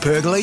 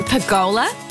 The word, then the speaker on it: pergola: Hey, have you seen Luke's new Sunview pergola yet? – Don't you mean pergola? – Pergola, pergola, it doesn't really matter how you say it.